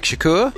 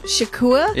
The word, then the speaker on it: Shakur: Tupac Shakur. – Shakur? – Yeah.